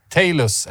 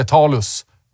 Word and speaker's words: Talus: Och amerikanska Talus eller Talus beroende på hur man vill uttala det.